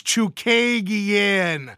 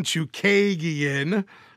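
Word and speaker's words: Chookagian: versus Caitlin Chikugian, or as Juice so abruptly and not very nicely sent me a text message saying, it's Chukagian. So sorry, buddy. I'll say it correctly. Caitlin Chukagian.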